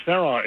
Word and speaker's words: Theron: And then Charlize Theron, and by the way, you can pronounce Charlize Theron. It's Theron, not Theron.